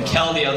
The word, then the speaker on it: Keldeo: So some of you guys said it right. It's Cavalion, Terrakion, Virizion, and Keldeo.